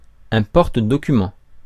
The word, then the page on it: porte-documents: France; [ɛ̃ pɔʁ.tə.dɔ.ky.mɑ̃]